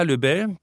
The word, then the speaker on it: Löbel: Vanessa Löbel, die Betonung liegt auf der zweiten Silbe, darauf legt sie großen Wert, schlägt den Kragen hoch und blickt entschlossen dem über der Nordsee aufziehenden Unwetter entgegen.